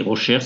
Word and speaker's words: Rauscher: Et Rocher, ça s'écrit R-A-U-S-C-H-E-R.